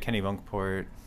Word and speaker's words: Kennebunkport: And, you know, what was it like growing up in Kennebunkport, if I said that right? – Yeah, you said it right. Kennebunkport. A lot of people say Kennebunk.